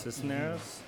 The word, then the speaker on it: Cisneros: And Quinceros, is that how you say it? He is actually their leading scorer. So. – Cisneros? – There you go.